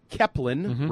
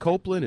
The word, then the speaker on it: Kepelin: And that's Chris Kepelin, rhymes with Zeppelin, was signed as a kicker to the roster. – You may remember him from last summer. – Yes, when we said, is it Copeland?